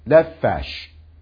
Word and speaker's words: nefesh: 那么 eight， nefesh， nefesh 重音放在后面， nefesh， nefesh 啊，所以注意这个是 f 的发音啊，不是 p 的发音，所以变成 nefesh， nefesh 就是 soul 啊，或是 life。